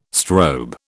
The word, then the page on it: strobe: British English